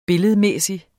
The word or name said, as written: billedmæssig